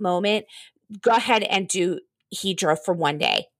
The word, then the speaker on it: Hydra: It's spelled Hydra in American. It's called Hydra.